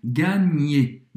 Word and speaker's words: gagner: Esse GN em francês tem o som do NH em português um pouco mais forte. Gagner.